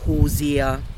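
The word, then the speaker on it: Hozier: Also es, ist, es wird H-O-H und dann S-I-E-R ausgesprochen, also Hosea.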